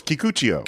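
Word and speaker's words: Kikuchiyo: No, it's Kikuchio. – Kikuchio, sorry.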